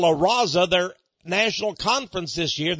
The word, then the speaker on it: La Raza: I would need to pronounce it, you know, La Raza.